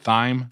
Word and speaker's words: thyme: Is that how you say it? Thyme? – Thyme. – Thyme. – Just thyme. – A hard T.